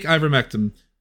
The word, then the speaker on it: ivermectin: take ivermectin.